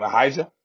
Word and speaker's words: Ahijah: Ahijah.